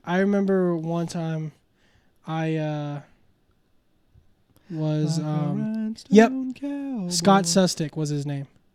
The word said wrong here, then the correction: Sustek: Scott and then S-U-S-T-E-K. – Oh. – Scott Sustic. Sustek. Yeah, Sustek.